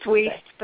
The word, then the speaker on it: Weiss: I believe it's Weiss is the correct pronunciation. – It's Weiss, but that's okay. – Oh, you pronounce it Weiss. See, and I'm used to the Jewish version, which is Weiss. So I'm like, technically, if you go by the spelling, it actually is Weiss, but a great uncle or somewhere around there actually switched the letters.